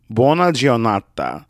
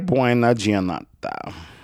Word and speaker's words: buona giornata: Buona giornata. Grazie buona, buona giornata. I'm saying that wrong. Let's find out how to say this correctly. Grazie buona giornata.